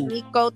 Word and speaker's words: Meeko: Cause if people want to go find my music, it's under Miko Taylor. So it's no offense. Y'all can call me Meek and all, but. – Emphasis on the O, Miko. – Yeah, Miko Taylor, M-E-E-K-O Taylor, and that's my real name, no gimmick.